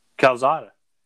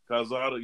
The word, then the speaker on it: Calzada: Calzada. – Calzada, yeah.